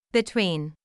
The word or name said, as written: between